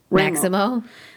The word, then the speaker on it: Maximo: Uh, according to Maximimo, Maximo Maximo. – That's how it said it. it said Maximo, and I was like, what?